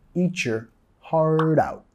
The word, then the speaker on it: eat your heart out: De la pronunciación eat your, eat your. Vamos a vincular ahí, también reducir el your a your, eat your, eat your, eat your heart out, heart out. Articulando con la R suave.